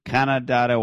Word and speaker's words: Canandaigua: So a few weeks ago, he broke into a $1 million, 6,000 square foot vacation home on Thai shores of Canandaigua Waiga Lake. I don't know how to pronounce that. – What country are we dealing with again? – Where this is in New York. Canandaigua.